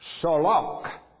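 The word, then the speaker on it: solok (צלח): It is a word that is pronounced solok, and solok means to push forward.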